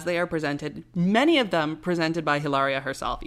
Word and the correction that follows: Hilaria: Ilaria, sorry. She corrects people and says the H is silent, so I'm not trying to be offensive. It is Ilaria.